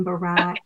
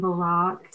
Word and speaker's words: Barak: Some people pronounce his name Barak and some Barak.